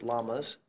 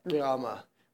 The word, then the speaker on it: llama: They used llamas as, or llamas, or actually, the correct way to pronounce llama or llama is neither of those. It's a sound that was made in Quechua that is not made in English or Spanish. that approximately sounds like Rama, which I can't do properly because I don't speak Quechua.